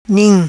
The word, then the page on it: Nim: patua